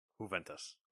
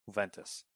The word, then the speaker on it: Juventus: Juventus. – Juventus.